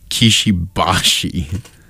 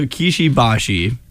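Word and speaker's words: Kishi Bashi: I call it Kishibashi. – Well, oh, that's totally... there's an A, not an I. – There it is. – That's how you pronounce it.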